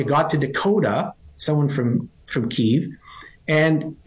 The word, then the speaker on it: Kiev: Did I say that correctly? Kiev? – Kiev. – Kiev. From Kiev.